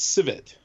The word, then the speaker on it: Civet: So it's Civet. – Civet. – Like Chevette. That's right. Civet.